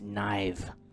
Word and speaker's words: knife: It's actually just pronounced knife. The K is silent.